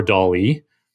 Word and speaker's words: DALL-E: If you go to the DALL-E blog post, so this is, it's pronounced like Salvador DALL-E, but it's spelt like the Pixar movie, the Disney Pixar movie, WALL-E.